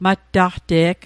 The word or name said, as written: mattaqte'g